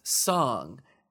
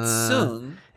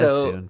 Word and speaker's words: Soong: It seems as if someone read on the page that it would be pronounced song, Dr. Noonien Song. – Often wrong. – But we've established that it's Soong. – Everybody says Soong, too. It's double O.